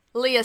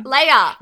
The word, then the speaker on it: Lea: and you were on team Leah Salonga. – Leia.